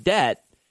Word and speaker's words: debt: D-E-B-T. Debt. I don't know. That I feel like the B is silent. Unless I say it like debt.